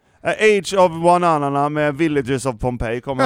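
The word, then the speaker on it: Ioannina: Villagers of Ionanina City. Jag kollade till och med upp hur de uttalade det.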